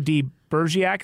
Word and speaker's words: de Bergerac: And by the way, Cyrano de Bergerac, is that how you say it? – De Bergerac, yeah.